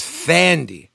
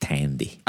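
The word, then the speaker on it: Thandie: I like that I thought it was Fandy. – It is, but it's. I think they pronounce it Tandy.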